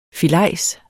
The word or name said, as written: FILEJSERNE